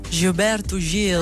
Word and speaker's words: Gilberto Gil: Ho letto che hai scritto una biografia dedicata a, non so se lo pronuncio bene, Gilberto Gil.